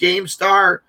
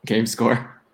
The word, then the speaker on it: GameScore: It's pronounced GameStar, GameStat? – GameScore.